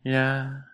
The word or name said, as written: lya